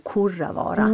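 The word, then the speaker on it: Kurravaara: lyssnare, att Kurravara uttalas som om du hade skrivit det med O, Kurravara.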